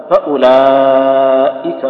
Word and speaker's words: فأولئك: ما نقول هذا مد بدل، زين ما نقول هذا مد بدل فأو، إن ما فيها مد، الواو اللي قدام طبعا اللي فاتح المصحف يكشفها أو اللي يعرف يتصور رسمها بيعرف إن فيه واو بعد الهمزة، ليس كذلك؟ فأو قالوا هذا لا تنطق، فأولئك هم.